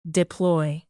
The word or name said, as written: deploy